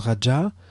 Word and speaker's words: Raja: Ça se prononce Raja.